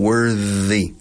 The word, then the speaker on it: worthy: Ahora, si añades Y, se cambia. La pronunciación worthy. TH vibrada. Like the basketball player James Worthy.